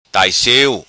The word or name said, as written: Tai Siu